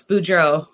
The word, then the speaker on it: Boudreau: So you guys, if you don't know, I want to say your name right. Boudreau. – Close. Boudreau.